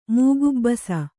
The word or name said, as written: ಮೂಗುಬ್ಬಸ